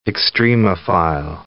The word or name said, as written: extremophile